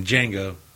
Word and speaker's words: Django: Like Jamie Foxx said in Django, the D is silent, motherfucker.